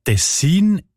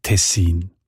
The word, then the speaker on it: Tessin: Auch im Hochdeutschen sieht man Tessin. Die Variante mit der Betonung auf der ersten Silbe und mit kurzem I, also Tessin, ist seltener.